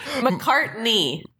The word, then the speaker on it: McCartney: How do I say it? – McCartney. – I said McCartney. – You said McCarty. – I said knee. – Listeners, he didn't have an N, and you know it.